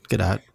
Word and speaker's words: Gadot: Gadot.